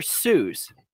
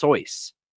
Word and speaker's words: Seuss: So, Dr. Seuss, that's not actually how you pronounce his name. He actually wrote a poem about how to pronounce his name, and it's actually pronounced Soyce.